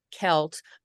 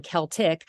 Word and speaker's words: Celt: The Irish language or the Gaelic language grouping has no soft C. So C-E-L-T should be pronounced Celt and Celtic. So they should be the Boston Celtics.